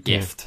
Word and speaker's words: GIF: Yeah, GIF, hard G. Yeah, like gift. Yeah. They've got, and then there's some people go with soft G, like GIF.